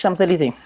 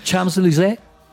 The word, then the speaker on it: Champs-Élysées: París, Champs-Élysées.